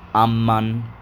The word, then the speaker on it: Amman: And he told us a little bit about Amman, or Amman, as I think it's meant to be pronounced, with an extra emphasis on the M's, A-double-M-A-N.